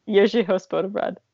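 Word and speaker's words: Jiřího z Poděbrad: Jiří Hospodobrad. – Yeah, there's like an R with the Haček in there, and that is like the impossible letter that's what I call it, the impossible letter.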